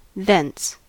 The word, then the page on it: thence: American English